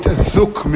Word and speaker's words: Zouk: Yes, and we call it Zouk music. Z-O-U-K or K-E for those who spell it differently.